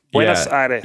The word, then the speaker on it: Buenos Aires: How do you say it? How are you supposed to say it? – I think... – Buenos Aires. Buenos Aires. – You have to have a little bit of a lisp as well.